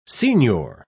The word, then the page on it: signior: British English